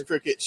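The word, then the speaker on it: Cricut: It's pronounced like little bug, but it's not spelled like a little bug. – I didn't know if it was cry cut or cricket. – It's cricket.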